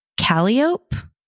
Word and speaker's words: Calliope: And then girls were Ivy, Lily, Ruby, which we have a Ruby in the family, Clover, Felicity, Calliope. I don't know if I totally botched that. C-A-L-L-I-O-P-E. Is that how you would pronounce that, Cari? – Yeah.